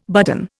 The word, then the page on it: button: Indian